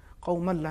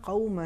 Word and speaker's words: قَوْمًا: انہوں نے تنوین کے بغیر تلفظ کیا ظاہراً, قوم اللہ انہوں نے پڑھا, چونکہ تکرار بھی کیا تو انہوں نے پھر وہی قوم اللہ پڑھا. شاید یہ ان کو دیکھنے میں مشکل ہوئی ہو, چونکہ یہاں پہ ایک تنوین ہے. لہٰذا صحیح تلفظ یہ ہے, لِمَ تَعِظُونَ قَوْمًا اللَّهُ مُهْلِكُهُمْ.